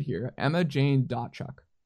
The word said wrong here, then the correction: Dotchuk: So it's Emma Jane and the T is silent, so it's Dochuk. It's not Dotchuk.